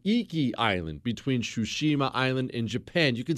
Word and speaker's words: Tsushima: Tsushima, I may be saying that wrong. Tsushima Island starts with a T, but Tsushima Island. Japan had a small defense force there.